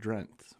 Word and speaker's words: Drenthe: But it's we pronounce it Drenth, Michigan. – Yes, as you should. – That's right. Awesome. – D-R-E-N-T-H-E, correct? – Yes.